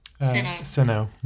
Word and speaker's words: Sinnoh: And you guys will have to help me out here with pronunciation. He says, while I was happy with the Sinnoh remakes. – Sinnoh. Mm-hmm. That's the region in down in Pearl. – Thank you for that.